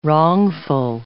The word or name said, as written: wrongful